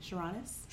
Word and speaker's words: Sarunas: I'll say it again. – Sharonis. – Sharonis. Sharon. – Sharonis. – Yeah, Sharonis. Yeah. – Sharonis. – Yeah, the spelling doesn't help at all. So. – No. How do you spell it? – It's S A R U N A S. – That's how they do it in Lithuania.